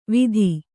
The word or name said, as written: ವೀಥಿ